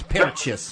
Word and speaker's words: Purchase: You have to say it correctly. It's Purchase.